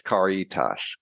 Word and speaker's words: Caritas: Well, from my perspective, the right way is Caritas.